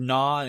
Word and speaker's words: gnaw: Apartment 212 just is kind of a more standard name, but like most people see the word "gnaw," it's a word that doesn't look particularly right because it's silent G.